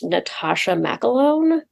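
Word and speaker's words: McElhone: Yeah, because Deirdre is like, I mean, I think her name is pronounced Natasha McElone, but I could be wrong. – No, that's how I've always heard it.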